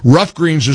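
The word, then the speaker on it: Ruff Greens: Rough Greens is spelled R-U-F-F.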